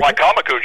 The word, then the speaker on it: Wicomico: Wicomico, George. – Wicomico.